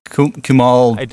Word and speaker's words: Kumail: The guy from Silicon Valley, Kamal Nanjiani. – Kumail Nanjiani.